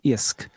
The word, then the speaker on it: iasc: But Hiskadi, that word there, IOSC, kind of to me sounds like the word in Irish for fish, which is I-A-S-C, isk.